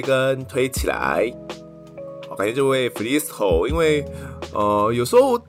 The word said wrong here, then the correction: Phyllis Ho: 那这个 Google 是念 Phyllis Who，所以就有点念错了，不好意思。